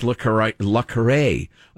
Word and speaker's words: Le Carré: Yeah, it's Le Carré, which is a pen name anyway, so call him anything you want.